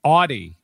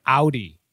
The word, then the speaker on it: Audi: I used to say Audi instead of Audi, and just like the whole world.